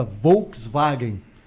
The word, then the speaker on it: Volkswagen: A origem do nome Fusca está relacionada com a pronúncia alemã da palavra Volkswagen. O fonema da letra V em alemão é algo como FAU, né? E o W é o V.